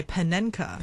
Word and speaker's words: Panenka: 呢个潘明卡？ – 佢其实英文名系 Panenka，咁所以佢哋就咁译出嚟嘅啫。 – 哦，应该叫潘明卡。